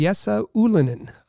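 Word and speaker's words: Jesse Ylönen: The correct pronunciation, and we've maybe we've all been saying it wrong, is Yessa Ulinen. Um, so we're going to have to start getting used to to saying it that way.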